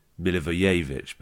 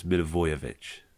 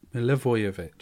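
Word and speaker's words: Milivojević: I wasn't sure if it was Milivojevic, but it's Milivojevic. – Milivojevic.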